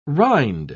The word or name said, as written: rind